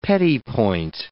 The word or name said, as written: petit point